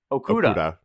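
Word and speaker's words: Okudah: Okuda. – I had to learn that for a radio spot because I was so used to saying it wrong. – Okuda.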